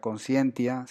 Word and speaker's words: conscientia: También tiene su origen en el latín, ah, se pronuncia conscientia, se puede ver allí que significa estar consciente o apercibido del bien y el mal.